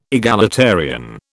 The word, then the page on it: egalitarian: British English